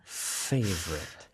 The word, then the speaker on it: Favorite: F 발음은? 바로 저 소리입니다. – Favorite. – Favorite. 이죠. 그래서 바람을 내뿜는 거를 익숙해지셔야 돼요. 우리말에는 사실 이 바람을 내뿜는 소리는 잘 없거든요. 그래서 favorite food. 거기에 또 V 발음까지 있네요. Favorite에. 근데 요 입의 모양은 똑같지만 V는 바람이 아니라 V 소리를 내는 거고요.